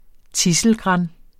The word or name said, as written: tidselgran